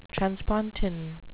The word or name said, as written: transpontine